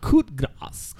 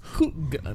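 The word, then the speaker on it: coup de grâce: Everybody's been saying it wrong. Close enough, right? Coup de grace. Coup de grace.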